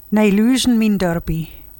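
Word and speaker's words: Nieuwleusen Mien Dörpie: Plaatselijk Belang Nieuw-Leuzen, afgekort tot PBN, heeft tijdens de algemene ledenvergadering het nieuwe dorpsambitieplan Nijluzen Mien Dörpie aangeboden. Zeg ik dat goed? – Nijluzen Mien Dörpie. – Dat is beter.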